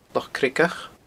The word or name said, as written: Loch Creagach